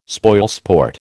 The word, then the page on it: spoilsport: American English